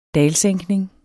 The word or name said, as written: DALES